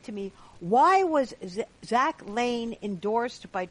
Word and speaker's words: Lahn: You mentioned that the guy that Zach Lahn, is it Lahn? Is that how it's, well, it looks like Lahn. It's pronounced Lane. – Okay. – It's L-A-H-N, but it's pronounced like Lane.